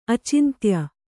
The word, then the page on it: ಅಚಿಂತ್ಯ: acintya